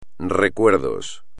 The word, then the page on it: recuerdos: espagnol